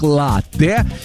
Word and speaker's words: латте: И знаете, это правильно, потому что слово итальянское, ударение там стоит на первый слог, латте. И вот, несмотря на обилие гласных, произносится твердо, без мягкости, латте.